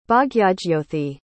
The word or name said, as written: Bhagyajyothi